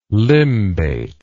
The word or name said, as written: limbate